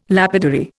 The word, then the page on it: lapidary: Indian